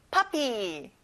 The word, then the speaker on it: puppy: 퍼피가 아니라 아 소리가 나요. puppy.